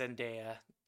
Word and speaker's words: Zendaya: Uh, it uh, says uh, Zendaya in oh. – this pronunciation guide.